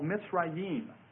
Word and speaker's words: Mizraim: His name is called Mizraim. – Mizraim. Spelled like this. – And that's seen in Genesis chapter 10 and verse 6. Genesis 10, 6. It's spelled M-I-Z-R-A-I-M, Mizraim. Now, Egypt was founded by him then after the flood.